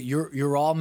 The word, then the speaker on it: Ural Mountains: Is it Ural Mountains? Ural? – U-R-A-L Mountains.